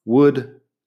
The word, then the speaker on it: would: Would, could y should tienen la misma vocal corta, es como una U corta, que no existe en español, y luego la L como si nada. Así que no intentes pronunciar una OU en medio porque es U. Uh, la L, nada. Would, could, should. Si dejas de pronunciar la L, ya estás a mitad del camino ahí.